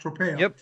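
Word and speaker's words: tropeion (τρόπαιον): The Greek word that St. John Chrysostom uses for war memorial is tropeion. I think that's how it's pronounced, tropeion.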